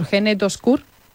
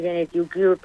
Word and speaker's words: Gene2Cure: Bueno, quiero también preguntarte, porque paralelamente continúa la investigación impulsada, a ver si lo digo bien, por Gene2Cure. – En inglés, Gene2Cure, pero sí, vale. Lo has dicho perfectamente.